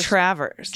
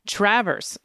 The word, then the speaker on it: Traverse: I think it's Traverse. – Traverse. I'm the one that keeps fucking this up, by the way. Traverse. Emphasis on the first syllable.